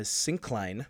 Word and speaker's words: syncline: The Punch Bowl is a deep canyon categorized by its plunging uh, syncline. I don't know if I said that right, Joe. Yeah, no, that's right.